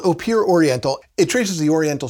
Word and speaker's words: Opihr: O-P-I-H-R is pronounced Eau Pure.